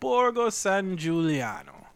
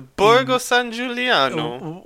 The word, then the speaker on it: Borgo San Giuliano: A semi-autobiographical tale about Tito, an adolescent boy growing up among an eccentric cast of characters in the village of Borgo San Giuliano. – You didn't say that right. You have to do the Borgo San Giuliano.